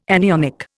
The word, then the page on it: anionic: Indian